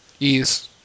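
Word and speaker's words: Ys: You got Valhalla Knights 2, Battle Stance, and how do you pronounce this? Wise 1 and 2? – Ease. – Ease, whatever. – Ease 1 and 2.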